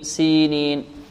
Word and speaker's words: سينين: هذا المبتدئين والذين يخطئون في هذه الكلمة، وإلا في المتمرس ينطق هكذا وطور سينين سينين، لكن المبتدئ يركز عليها ويركز على أن هناك ياء مدية بعد السين، هكذا وطور سينين سينين سينين، والتين والزيتون وطور سينين، وهذا البلد الأمين، وهذا الذال هنا ماذا؟